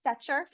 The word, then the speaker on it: Stecher: Hello for the Whiny Palooza podcast, and I am so excited to have Melissa Stetcher, did I say it right? – Yes.